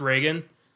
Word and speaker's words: Regan: And Regan, it's spelled Regan. It's pronounced Reagan.